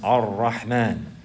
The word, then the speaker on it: Ar-Rahman: Ar-Rahman, Ar-Rahim. There's two Ra's. You have to pronounce both of them. And you can't say Ar-Rahman like this. Rather, that's skipping a letter.